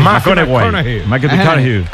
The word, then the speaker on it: McConaughey: Hay que ir corrigiendo la pronunciación. – McConaughey.